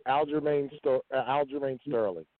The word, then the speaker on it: Aljamain Sterling: Aljamain Sterling.